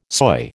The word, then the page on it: soy: American English